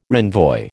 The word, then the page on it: renvoi: American English